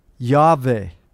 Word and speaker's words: Yahweh: No one knows the pronunciation of this name. More than likely, though, we call him Yahweh. There's no W sound in the Hebrew.